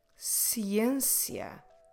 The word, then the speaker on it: ciência: Ciência.